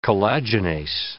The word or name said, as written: collagenase